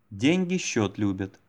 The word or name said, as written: деньги счет любят